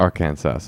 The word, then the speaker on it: Arkansas: That's how you pronounce it. Arkansas.